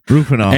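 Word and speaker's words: Rohypnol: And there is some debate as to what happened there, because Cobain took, it was a ridiculous amount of pills, a Rolifenol. I can never pronounce it. – Rufinol.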